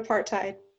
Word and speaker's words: apartheid: The apartheid. – That there was a truth and recollection? – Reconciliation. I got it. So the question is, in South Africa, after the apartheid,